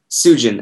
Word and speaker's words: Sujan: Sujan, actually.